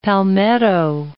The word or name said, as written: palmetto